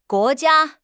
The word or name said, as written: guo2 jia1